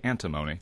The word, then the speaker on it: antimony: Yeah, that's how I always pronounce it, but it's actually antimony.